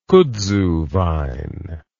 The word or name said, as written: kudzu vine